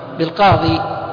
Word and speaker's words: القاضي: بالقاضي، لا ننطق الكسرة، لا نقول قاضي، يعني فيها نوع من الثقل.